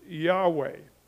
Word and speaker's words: Yahweh: As far as we're concerned, we would pronounce this Yahweh.